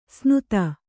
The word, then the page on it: ಸ್ನುತ: snuta